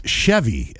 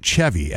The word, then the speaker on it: Chevy: All right, so now I'm being told that it's Chevy, as in the vehicle. Everything I am reading says it's Chevy, as in chair or chase.